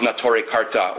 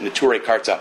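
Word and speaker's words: Neturei Karta: out of which grows Nature Karta, or as we mispronounce it, Nature Karta, and other groups.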